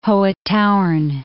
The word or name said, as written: Hohe Tauern